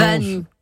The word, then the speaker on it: Wany: C'est comme ça qu'on prononce. – Wany. Oui, Wany, comme dit Valérie.